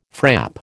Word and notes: frap: American English

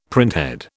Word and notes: printhead: British English

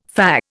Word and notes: fah: Indian

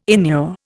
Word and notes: inure: Indian

